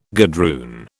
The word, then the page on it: gadroon: British English